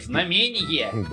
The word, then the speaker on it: знамение: Знамение! – Да, или знамение, как вам больше нравится ударение.